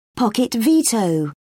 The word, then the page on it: pocket-veto: British English